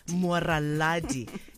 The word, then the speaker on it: Mwaraladi: Mwaraladi. – You see, they spelled your name wrong. They said Mwaradi before. Mwaraladi.